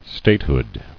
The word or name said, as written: Statehood